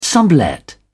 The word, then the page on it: sublet: British English